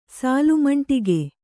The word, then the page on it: ಸಾಲುಮಂಟಿಗೆ: Kannada